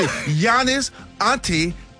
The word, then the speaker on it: Giannis Antetokounmpo: Did I say it right? – Did he say it? – Let me see. Giannis Antetokounmpo. Tacumpo. – There you go. – Come on now. – Yeah, you got it right, baby.